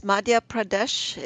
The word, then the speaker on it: Madhya Pradesh: Now, you went to Madhya Pradesh. Is that how you pronounce it? – Yes, Madhya Pradesh is a very large central state of India.